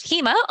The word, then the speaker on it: HEMA: So I would have pronounced that HEMA until we met. But HEMA is the correct pronunciation.